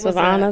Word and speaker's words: Savona: And they lived in a small town in New York called Savona. And it was, I may say that wrong, but it's S-A-V-O-N-A. – Yeah, but it Savona, was Savona.